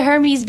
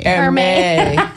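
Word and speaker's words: Hermès: Or is it Hermes? – Hermes. – I've only ever read that word, okay? – It goes past the Great Poupon. It's not jelly. – I knew I said that wrong as soon as I said it.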